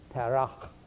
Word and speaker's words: Terah: And the first 400 years were basically spent, you remember that there was a man called Terah. How would you say that more properly? Abraham's father. How would you say that? Terach, with a ch on the end.